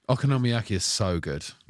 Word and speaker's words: okonomiyaki: Okonomiyaki.